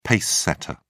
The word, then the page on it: pacesetter: British English